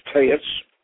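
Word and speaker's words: TEX: which is Taetz, and I spelled text, by the way, but it's pronounced Taetz.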